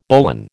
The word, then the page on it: bowline: American English